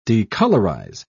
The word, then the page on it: decolorize: British English